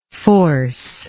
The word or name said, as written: forcingly